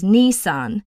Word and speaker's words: Nissan: Having lived in the Greater Toronto area since 2009 and listened to all of the local radio ads, I assure you I am more than aware that you pronounce it as Nissan.